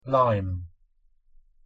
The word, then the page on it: lime: English